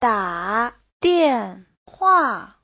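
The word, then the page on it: dadianhua: da3dian4hua4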